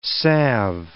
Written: salve

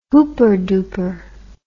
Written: hooper dooper

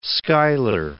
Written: Schuyler

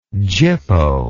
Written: gyppo

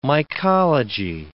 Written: mycology

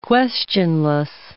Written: questionless

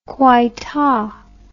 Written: coaita

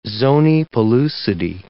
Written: zonae pellucidae

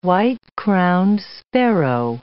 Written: white-crowned sparrow

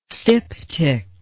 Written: styptic

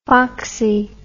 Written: foxie

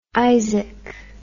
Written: Izaak

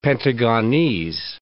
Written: Pentagonese

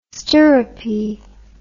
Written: Sterope

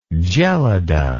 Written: gelada

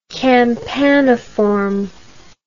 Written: campaniform

